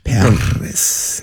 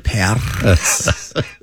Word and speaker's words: Paris: Not spelled with two R's. Not like the city. Age 24, Kristen. Hey. – Paris. – Bite and scratch. – Paris. That's how you pronounce it. – How do you pronounce it? – Paris. – One more time. – Paris. – There we go.